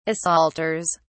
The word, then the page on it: assaulters: British English